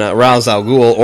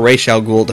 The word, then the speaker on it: Ra's al Ghul: On that one, it was so focused on Ra's al Ghul, or Ra's al Ghul, depending upon how you want to pronounce it, which...